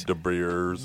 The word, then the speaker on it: De Beers: De Beers? Is that how you say it? De Beers? – De Beers. – De Beers. Yeah, De Beers. – Yeah, I think it's De Beers.